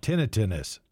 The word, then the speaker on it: tinnitus: I know you're in Western New York, but out here in Colorado, we see so many TV ads now about tinnitus.